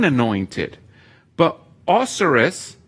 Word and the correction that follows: Osiris: or Osiris, I should say, poor pronunciation there, I apologize, and Mithras were both called Christos, which means good.